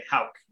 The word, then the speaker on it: Howk: It's How with a K, Howk. Nothing rhymes with it.